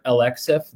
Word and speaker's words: Alexif: If you said the letters L, X, and then F together, Alexif, that's how you say it.